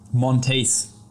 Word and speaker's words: Monteith: Monteith.